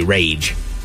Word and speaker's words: Rayge: A summer camp counselor shared the names of some triplets: Riot, Rage, and Kathleen. – I think Kathleen will be the most successful. – I think she will. But don't worry, they spelled them different. It's R-Y-A-T-T, Riot. R-A-Y-G-E, Rage.